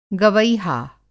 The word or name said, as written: गवैहा